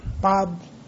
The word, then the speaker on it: Bob: Bob Doerr. Bob, B-O-B. – Got the Bob. – B-O-B as in Doerr.